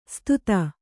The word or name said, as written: ಸುದತಿ